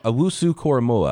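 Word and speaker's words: Owusu-Koramoah: Owusu Koramoa. I'm going to make sure I say his name right. – Owusu Koramoa, I believe.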